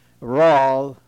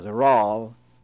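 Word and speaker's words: Rasle: When you see R, that means Rawl, R-E-S-L-E. It is spelt R-E-S-L-E, but it is pronounced as Rawl.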